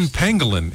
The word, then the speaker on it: pangolin: How do you pronounce them again? Pangolin. It's P-A-N-G-O-L-I-N.